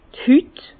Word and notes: d' Hüt: Bas Rhin; Herrlisheim

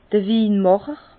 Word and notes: d'r Winmàcher: Bas Rhin; Herrlisheim

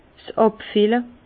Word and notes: 's Àbfille: Bas-Rhin; Herrlisheim

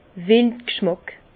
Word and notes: wild g'schmàck: Bas Rhin; Herrlisheim

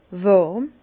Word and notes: wàrm: Bas-Rhin; Herrlisheim

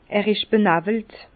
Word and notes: er isch benàwelt: Bas-Rhin; Herrlisheim

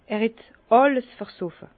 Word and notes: er het àlles versoffe: Bas-Rhin; Herrlisheim

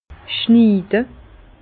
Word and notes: schniide: Bas-Rhin; Herrlisheim

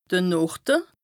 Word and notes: de Norde: Bas-Rhin; Strasbourg

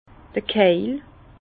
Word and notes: d'r Keil: Bas Rhin; Herrlisheim

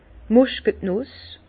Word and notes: d' Muskàtnuss: Bas Rhin; Herrlisheim

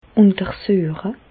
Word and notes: untersüeche: Bas-Rhin; Reichshoffen

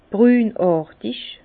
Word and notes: brünàrtig: Bas-Rhin; Herrlisheim

